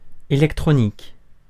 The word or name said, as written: electronic